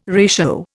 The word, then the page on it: ratio: Indian